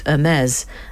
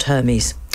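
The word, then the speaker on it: Hermès: I've only just learnt it is, in fact, Hermes and not Hermes.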